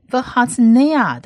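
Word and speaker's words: v'hatznea (והצנע): 再来往下一个字母是希伯来文字母的 nun 在这边呢发音是发 n 的音，而它下面的这个母音是长母音 a，所以是 nay v'hatznea 的 nay。那最后一个字母是 ayin，希伯来文字母的 ayin 不发音，但它下面的母音要发音，它下面的母音就是短母音 a，所以这整个字呢就是 v'hatznea 是谦卑的意思，谦卑的。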